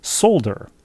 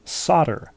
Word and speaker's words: solder: For several years as a new middle school teacher, my sister taught her eighth graders a word that she pronounced solder. Off they went into the world, and only after several years did she discover that it's pronounced solder. Oops, but she laughs about it now. Solder, S-O-L-D-E-R. Of course you would pronounce that solder if you hadn't heard.